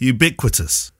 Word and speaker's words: ubiquitous: How do you say that? – Ubiquitous. – Ubiquitous. – No, ubiquitous. Ubiquitous.